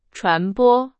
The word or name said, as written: 传播